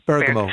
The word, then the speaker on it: Bergamo: Right. Bergamo. Is that how you pronounce it? – Bergamo, yeah. – Yeah. – That's it.